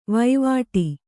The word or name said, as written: ವೈವಾಟಿ